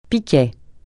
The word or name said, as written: piquet